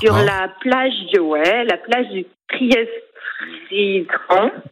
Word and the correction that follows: Trestraou: Trestraou. – Je prononce bien.